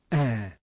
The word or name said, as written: ash